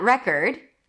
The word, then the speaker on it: record: Here, the re is the primary stress, as in red. Curd, or it's a world record, right? Same pronunciation. Record, or I need to record it. R, then it's a schwa. Cord. All of a sudden, it's an aw sound, as in four. Record, right? In record, it was schwa. You couldn't hear an aw sound. But here, record. See how it works?